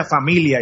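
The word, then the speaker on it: famiglia: Eh, de paso, en, en, en, italiano no se pronuncia la G, Así que aunque se escriba familia, se pronuncia familia, igual que en español.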